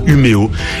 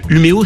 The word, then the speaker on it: Umeå: D'abord parce que Umeå s'écrit Umeå, avec un drôle de petit accent sur le A qui se prononce O en suédois.